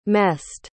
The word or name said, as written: messed